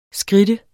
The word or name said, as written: skridte